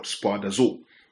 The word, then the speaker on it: spoudazo: So "do thy diligence" is a single word in the Greek, and the word in the Greek is the word spoudazo, S-P-O-U-D-A-Z-O, spoudazo, spoudazo, spoudazo. Well, the pronunciation might differ from people, but really it's a Greek word, right?